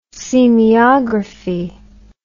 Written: semeiography

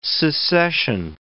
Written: secession

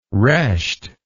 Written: Resht

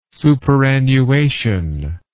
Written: superannuation

